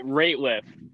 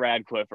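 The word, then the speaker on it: Rateliff: I don't know if it's Nathaniel Radcliffe in the night sweats. It's Nathaniel something in the night sweats. Ratliff, not Radcliffe, Ratliff.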